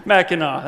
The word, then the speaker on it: Mackinac: Mackinac.